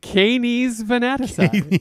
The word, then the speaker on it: Canes Venatici: I mean, if you're going to a constellation, you might as well go to Canes Venatici.